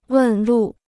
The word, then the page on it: 问路: wèn lù